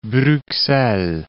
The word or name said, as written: Bruxelles